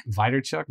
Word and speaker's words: Vaynerchuk: Vaynerchuk.